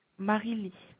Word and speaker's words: Mariylie: Marie-Lie.